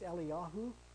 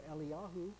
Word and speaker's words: Eliyahu: And you know, those names seem pretty similar, but if you pronounce them in the Hebrew, it's Eliyahu and Elisha is really the way that the Hebrew would announce those two names. And so they're not quite as similar when you pronounce them that way.